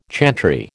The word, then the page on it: chantry: American English